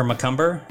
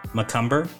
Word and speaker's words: McCumber: You can find me on Twitter at Parker McCumber. That's spelled P-A-R-K-E-R underscore McCumber, M-C-C-U-M-B-E-R.